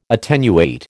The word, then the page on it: attenuate: American English